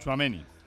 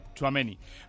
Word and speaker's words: Tchouaméni: Bueno, yo no es que domine mucho el francés, pero, en fin, la manera de pronunciarlo, Tchouaméni, ¿no? Más o menos. – Tchouaméni. – Aurélien Tchouaméni. – Tchouaméni.